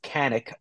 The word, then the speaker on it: Kanik: Yeah, so it's pronounced Kanik. So people have started calling me Kanik because Matt just fucked up my name once and just rolled with it.